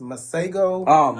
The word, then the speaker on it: Masego: I want to say that his name is pronounced Masego. – Masego.